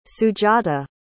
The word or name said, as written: sujata